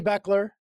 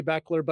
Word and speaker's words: Bechler: And my last name is not spelled the way it's pronounced. So I'm always getting my name butchered a little bit, but Jamy Bechler.